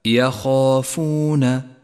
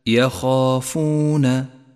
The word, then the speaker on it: يخافون: لاحظ بعده كلمة يخافون، يخافون، جاء حرف الخاء مفتوحا وبعده حرف الألف، فيفخم بأعلى مرتبة من مراتب التفخيم، أي هكذا يخافون، يخافون.